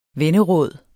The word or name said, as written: venneråd